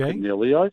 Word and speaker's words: Caniglio: But those, like, you've got the three big guys, Dunkley, Taranto, and Caniglio. Sorry, with the G pronunciation. – Double G. – Caniglio. Every time.